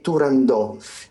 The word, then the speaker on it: Turandot: che dice proprio espressamente che non si dice, non si pronuncia con la T finale, ma invece è realmente Turandò.